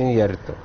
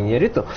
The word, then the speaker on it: Иньярриту: Правильно говорится, Иньярриту, а не Иньярриту.